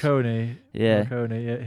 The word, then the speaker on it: Morricone: Errico Morricone, I think is how his name's pronounced. – Morricone.